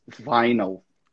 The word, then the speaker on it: vinyl: Vinil. – The correct pronunciation is vinyl.